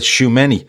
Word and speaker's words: Tchouameni: I asked Keith earlier how I pronounce his name. He said Tchouameni, um, the midfielder from Monaco.